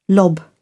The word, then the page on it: lob: British English